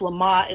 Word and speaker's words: Lamotte: Some of you may know Alfred, I think it's pronounced Lamotte, it's spelled L-A-M-O-T-T-E.